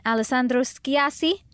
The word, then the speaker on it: Alessandro Schiassi: I have June Johnson and Alessandro Schiassi.